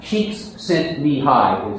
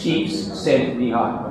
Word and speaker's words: Csikszentmihalyi: Mike Csikszentmihalyi, the flow guy, Csikszentmihalyi is how his name is pronounced. Csikszentmihalyi.